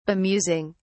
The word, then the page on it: bemusing: British English